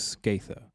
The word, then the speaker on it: Scatha: And uh, we found out earlier today that uh, we think the pronunciation is Skatha.